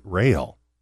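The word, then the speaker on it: Roehl: So my real name is Gary Rael, but it's R-O-E-H-L, very difficult to pronounce.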